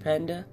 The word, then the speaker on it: Panda: I'm your host, Akilosh Panda. Yes, my name is spelled like the animal.